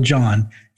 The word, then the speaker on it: Jon: It doesn't matter how you spell John, you will get to my website.